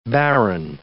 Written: barren

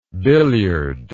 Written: billiardist

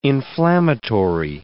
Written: inflammatory

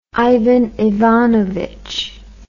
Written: Ivan Ivanovitch

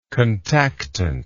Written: contactant

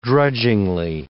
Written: drudgingly

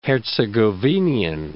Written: Herzegovinian

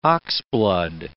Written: oxblood

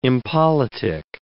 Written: impolitic

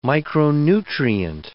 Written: micronutrient

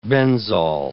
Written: benzol

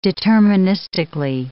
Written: deterministically

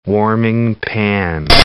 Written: warming pan

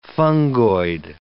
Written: fungoid

